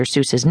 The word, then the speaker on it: Seuss: Like the fact that superb fairy wrens are the least faithful species of bird in the world, or that the original pronunciation of Dr. Seuss's name rhymed with Joyce, or that Leonardo da Vinci invented the first machine gun, which shouldn't really surprise anyone since he invented thousands of things.